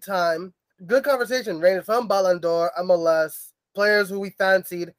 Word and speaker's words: Ballon d'Or: Well, should I try to pronounce it right, like my boy Sebastian at Campfire Football, the Ballon d'Or.